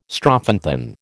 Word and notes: strophanthin: American English

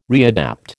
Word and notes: readapt: American English

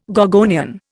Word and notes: gorgonian: Indian

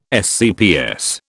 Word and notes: SCPS: British English

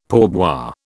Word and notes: pourboire: British English

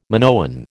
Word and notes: Minoan: American English

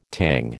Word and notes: Tang: American English

